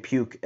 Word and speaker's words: Puk: And maybe this year will be the first year that we get to see AJ Puk in a first year. And that's not me mispronouncing it, I think. That's actually his name.